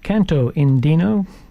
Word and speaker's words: Canto Andino: We had Hermanos Gutierrez with Canito Andino. Canto Andino. My pronunciation tonight's way off.